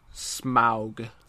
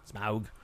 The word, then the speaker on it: Smaug: Honestly, I know that would be blasphemy for uh, LOTR fans, but I I like Desolation of Smog a lot. – Smog. I keep saying it wrong. Smaug. Smaug. Smaug.